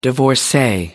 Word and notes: divorcee: American English

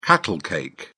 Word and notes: cattle cake: British English

